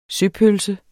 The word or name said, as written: søpølse